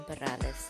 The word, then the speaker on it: Perales: And this is your host, Kim Perales. If you cannot roll your R's, it's okay.